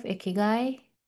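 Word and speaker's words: Ikigai (生き甲斐): And just to throw it out there, have you heard of Ikigai? – I mean, I have, but let's ask our audience, have you heard of this Japanese concept, Ikigai? Is that how you say it? – Yes, it's Ikigai. So it is a Japanese concept about your reason for being.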